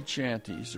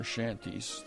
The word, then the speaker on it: shanties: So I put a very short piece before it, which is the Vaughan Williams Sea Songs, which are three sea chanties, or shanties, they're pronounced in England, woven around the woodwinds and the French horns and the brass.